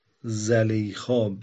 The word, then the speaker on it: زلیخا: زلیخا یعنی به فتح زا و کسر لام میگیم، زلیخا درسته، زلیخا درسته، تلفظ اسم زلیخاست که حالا ما، حالا غلط مشهوری شده بین ما که زلیخا میگیم.